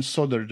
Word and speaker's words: solder: Do you often solder the aluminium? Because there's another thing that you over there pronounce differently. – Say solder and solder. – Do you say it with an L or without an L? – With an L.